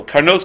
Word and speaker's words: Karnos: It says Karnos 3 times, and the way to pronounce it all 3 times is in the plural. Karnos, Karnos, Karnos.